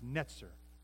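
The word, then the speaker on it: Netzer (נצר): The, before Nazareth was even a town, there's a Hebrew word called Netzer, pronounced Netzer. N E T Z E R.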